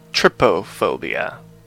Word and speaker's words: trypophobia: What is it, tryptophobia? Am I saying that right? – I think it's trypophobia.